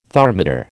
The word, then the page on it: thermidor: American English